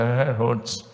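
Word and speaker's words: Herrnhut: Eu não falo nada de alemão, por isso a pronúncia é portuguesada, mas é Herhut. É, é, é, é por aí.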